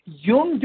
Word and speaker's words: Jung: So in 1931, Roland Hazard went to Geneva, Switzerland, to place himself under the care of Dr. Carl Jung, J-U-N-G, pronounced Jung.